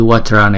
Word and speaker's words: vajra: Chữ vajra này là hai âm. Vajra. Chữ j này chúng ta đọc là j. Vajra, vajra.